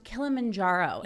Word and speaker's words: Kilimanjaro: As you've just recently climbed Mount Kilimanjaro. Yes, am I pronouncing this correctly? – You are.